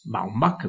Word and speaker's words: Baumbach: La sanno correggetemi subito. Noah Baumbach. – Baumbach, perfetto.